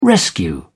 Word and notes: rescue: British English

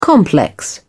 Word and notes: complex: British English

